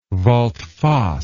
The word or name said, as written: volte face